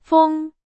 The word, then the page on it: 风: Chinese